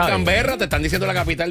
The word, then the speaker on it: Canberra: C, Canberra. – Es Can, ¿ok? – ¿Cómo se dice? – Canberra.